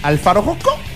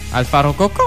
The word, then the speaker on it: Alpha Rococo: Il faut prononcer Alpha Rococo. – Alpha Rococo. – Comme un perroquet.